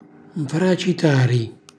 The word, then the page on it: nfracitari: calabrese